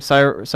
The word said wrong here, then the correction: Saoirse: Ronan? – Saoirse Ronan.